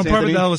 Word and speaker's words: pappardelle: Did I mispronounce pappardelle? Or you say prosciutto? Yes. – No, pappardelle is fine.